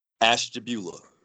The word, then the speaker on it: Ashtabula: Ashtabula.